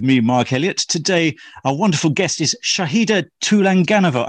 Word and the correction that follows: Tulaganova: I do hope I've said that right. – Not right. Tulaganova. – Okay, say it again. – Don't even try. Shahida Tulaganova. – Tulaganova. Okay, so I stress the over.